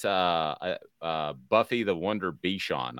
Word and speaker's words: Bichon: Bichon. Is that the way you pronounce that dog? – Bichon, yeah.